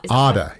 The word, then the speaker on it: Arda: But for my sake, Arda rhymes with it rhymes with harder.